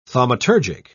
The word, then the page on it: thaumaturgic: British English